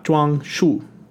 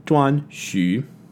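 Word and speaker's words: Zhuanxu: It is not Zhuangshu. It is Zhuangxu.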